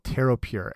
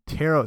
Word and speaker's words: pTeroPure: So this is actually a compound or what they call a co-crystal that combines caffeine and something called pTeroPure. And tero, so that's P silent P T E R O P U R E.